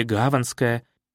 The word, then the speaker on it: Гаванская: Даже названия морские, улица Шкиперский проток, к примеру, или Гаванская. Именно с ударением на первом «а», от слова «гавань», а не «гаванна».